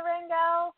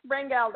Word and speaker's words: Rangel: So, anyway, yeah, it's Rangel, though. You got that right.